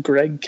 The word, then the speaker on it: Gregg: They can find me on Twitter at Greg Katzman. My first name is spelled G-R-E-G-G, and last name starts with a K, and then should pop up from there.